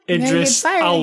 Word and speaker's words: Idris Elba: Idris Elbow. – Correct. Oh. don't, don't say his name bad. Idris Elba.